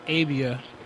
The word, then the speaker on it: Abia: Abia. – Avia? – Yeah. – Could you spell it for me? – First name Al, last name A-B-I-A. – A-V-I-A. – A-B as in boy, I-A.